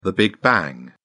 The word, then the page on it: big bang: British English